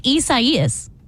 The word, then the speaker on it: Isaias: I-S-A-I-A-S. – And it's four syllables. I do know that. I S A I A S. And it's like I S A I A S. – Isaias. I think that's how it is.